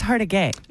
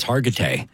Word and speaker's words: Hargitay: I thought it was Hargitay. – No, it's Hargitay.